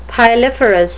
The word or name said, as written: piliferous